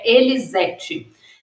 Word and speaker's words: Elisete: Vocês viram no vídeo, né, que o nome da pesquisadora-chefe é Elisete Leão. Eu falei errado, mas como vocês viram lá no vídeo, é Elisete.